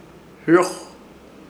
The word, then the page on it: Hɨroj: Ishir Chamacoco